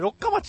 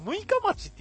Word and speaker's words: 六日町: はい、俺も中学までは、南魚沼の、これ俺六日町って読みましたけど、これ六日町って言うんですね。